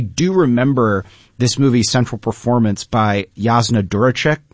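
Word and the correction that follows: Đuričić: By the way, her surname is Juricic.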